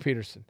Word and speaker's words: Pederson: Did I say his name wrong? – You said Patterson. – Oh, excuse me. Doug Pederson.